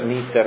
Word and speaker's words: Knitter: Un libro importante, editato da Campo dei Fiori, Nitter con la K iniziale. Come? Knitter, sarebbe, sì, anche se si pronuncia Nitter senza.